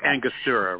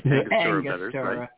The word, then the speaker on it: Angostura: But you can use the Aragosta, what a fuck. – Angostura, right. – Angostura. – Right. – Always mispronounce that.